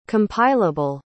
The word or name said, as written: compileable